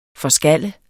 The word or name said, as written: forskalle